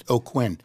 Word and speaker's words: Aucoin: And you spell his name; his last name is A U C O I N, and it's pronounced O'Quinn.